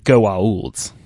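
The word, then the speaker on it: Goa'uld: You hate the Gould. I hate the Gould. Everybody hates the Gould. Although he pronounces it Goa'ulds.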